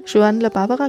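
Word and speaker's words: Joan La Barbara: In diesem Fall von, äh, wie spricht man es richtig aus? Sean oder John La Barbara? – Ja, Sean La Barbara.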